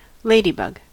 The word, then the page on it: ladybug: American English